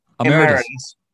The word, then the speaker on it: Emeritus: Did I get that right? I probably pronounced Emeritus. – Emeritus. – Yes.